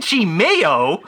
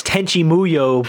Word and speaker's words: Tenchi Muyo: They didn't even get the name right. Tenchi Meyo. M-E-Y-O. No, that's not right. – I didn't catch that when I read it. It's Tenchi Meyo. – Tenchi Meyo. – It's Tenchi Muyo, people.